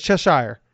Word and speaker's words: Cheshire: It is spelled Cheshire. That is Cheshire. – Cheshire. Yeah. – I've seen The Hobbit. It's Shire. Shire.